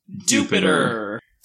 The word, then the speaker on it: Dewpider: I just caught Dewpider. – As Seth has repeatedly informed me against my will, it is pronounced Dewpider.